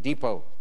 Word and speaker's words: depot: What is T? – T in depot.